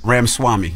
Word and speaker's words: Ramaswamy: Did I say his name right? – I think it's Vivek Ramaswamy. – Vivek Ramaswamy. – I think that's right.